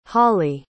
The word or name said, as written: holli